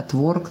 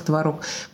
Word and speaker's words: творог: С ударением та же ситуация, одновременно, одновременно, творог, творог. Поэтому я бы сказала, что расслабьтесь и говорите, как хотите, шайбер, шабер.